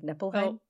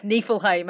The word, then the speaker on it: Niflheim: I'm pretty sure I mispronounced Niflheim last episode because Ewan was laughing because I said it like Nippleheim. Oh. And he's like, it's Niflheim.